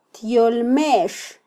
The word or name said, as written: tyolmex